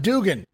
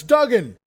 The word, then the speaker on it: Duggan: It's not Dugan, that's Duggan. Two G's, tough guy.